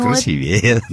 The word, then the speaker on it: красивее: Все дело в том, что похожие слова «сильнее», «добрее», «быстрее» состоят всего из двух слогов. В них ударение ставится на предпоследнее «е». А что же касается слов, например, там, каких «прекраснее», «удивительнее», ну и, конечно, «красивее», в них перед буквой «е» больше двух слогов. В этом случае ударение на предпоследний слог не ставится.